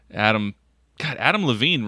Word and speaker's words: Adam Levine: Adam Levine rhymes with Adam and Eve.